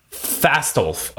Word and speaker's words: Fastolf: And of course, one more bathroom story, which is that, I can't pronounce this, but how do you say Dr. Fassifel? I say Fastolf. – Fastolf. Okay, Fastolf. That's better.